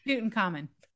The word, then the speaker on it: Tutankhamun: So in the 1920s and 1930s, there were 6 murders in Britain that were blamed on the curse of Tutankhamun, if I'm saying that right. – Tutankhamun.